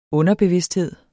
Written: underbevidsthed

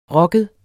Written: rocket

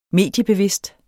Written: mediebevidst

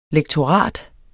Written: lektorat

